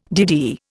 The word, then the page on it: DD: Indian